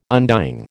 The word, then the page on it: undying: American English